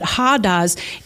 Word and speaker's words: hodos: Hados, it's written H-O-D-O-S, but you pronounce it Hados, is a traveled way or a road.